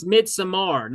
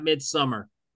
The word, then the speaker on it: Midsommar: It's Midsommar, not Midsummer.